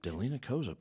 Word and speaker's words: Delinah Kozup: Delinah Kozup.